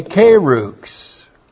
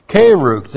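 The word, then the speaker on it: keruks (κῆρυξ): And from the verb keruso comes one of the words for a pastor teacher, a keruks. looks like that. K long E R U K S. Kerukhs.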